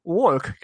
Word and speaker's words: walk: Lo mismo pasa con walk, que es andar. Walk.